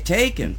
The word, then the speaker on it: Tacon: Tacon, okay, interesting.